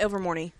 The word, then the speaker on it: Ilvermorny: Chadwick and Webster are brothers, were also the adopted sons of Ilvermorny. Am I saying that? I'm saying that wrong or not? School. – Yeah, Ilvermorny.